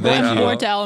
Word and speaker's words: Wardell: Wardell, am I saying that right? – Yeah.